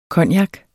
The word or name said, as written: COGNACERNES